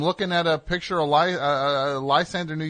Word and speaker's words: Lysander: S-A-N-D-R. – E-R. – E-R. L-Y-S-A-N-D-R-E-R. – No. L-Y-S-A-N-D-E-R.